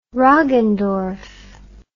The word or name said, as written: roggendorf